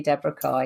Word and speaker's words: Deborah Carr: No, my name is, as it's written, really Deborah Carr.